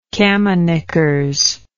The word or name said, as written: camiknickers